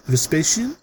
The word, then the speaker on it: Vespasian: Did I say that right? Vespasian? – I think so.